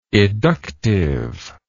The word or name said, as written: eductive